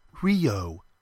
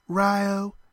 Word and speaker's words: Rio: "Not Rio," she said. "Round here we say Rio, you know, to rhyme with Ohio."